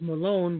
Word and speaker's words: Maloon: That confused me too because it's pronounced Malone, but it's spelled Maloon.